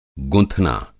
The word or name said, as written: गुँथना